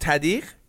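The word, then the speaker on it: tahdig: So it's called tadir. And tah means bottom. And dir means pot.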